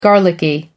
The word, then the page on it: garlicky: American English